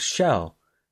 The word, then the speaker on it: Chell: Because Chell is it, I hear it a lot of times called it her, a lot of times called Chell, and a lot of times called Shell. And I don't know what her name... I think it's Chell. – It's C-H-E-L-L, so pronounce it how you wish. – Well, because I think if you listen to the dev commentary for Portal 2, I believe Gabe Newell pronounces it Chell.